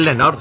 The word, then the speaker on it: Lenore: La tercera novedad está aquí, yo no sé si se pronuncia Lenor o Lenore. Collejas. – No lo sé yo tampoco. – Yo creo que es Lenor, sí. – Sí, es Lenor.